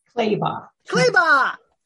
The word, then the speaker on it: Clabaugh: Clabaugh.